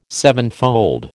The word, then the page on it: sevenfold: American English